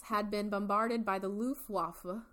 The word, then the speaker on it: Luftwaffe: I watched Pearl Harbor a lot, and that is how it is said. – I love that. – Luftwaffe.